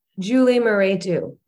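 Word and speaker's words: Julie Mehretu: Julia Meretu. I'm not sure I'm pronouncing the name. – Julie Meretu.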